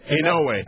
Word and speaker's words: Inouye: Inouye. – Inouye of Hawaii,